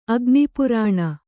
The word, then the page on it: ಅಗ್ನಿಪರ್ಣಿ: agniparṇi